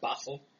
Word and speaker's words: Basel: Philology, whatever, at the University of Basel in 1869. If I can't pronounce it, I don't even know. – Yeah, Basel.